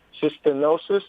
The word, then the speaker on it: cystinosis: You're a patient advocate for cystinosis.